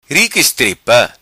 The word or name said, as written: Ri Qui Istripa